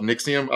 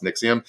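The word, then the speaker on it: NXIVM: For the sex cult pronounces itself Nixium. I don't know why they spell it in Roman numerals, but it's just pronounced Nixium.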